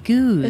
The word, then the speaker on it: Goed: Okay, Goose. – It's like booze, but with a G.